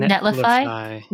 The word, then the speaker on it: Netlify: That one is, I have to remember what the URL is, mostly because, is it Netify? I can never spell that correctly. – Netlify? – Netlify.